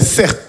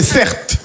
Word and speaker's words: Certe: Je m'appelle Sert. Mon prénom, c'est Sert. Ça s'écrit C-E-R-T-E.